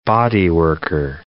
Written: bodyworker

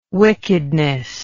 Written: wickedness

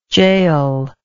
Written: jael

